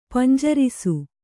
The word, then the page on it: ಪಿಂಜರಿಸು: pinjarisu